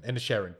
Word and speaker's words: Inisherin: So all last night I was like, I'm going to say that right. I know how to say it. And now I've done it wrong again. Inisherin. Banshees of Inisherin.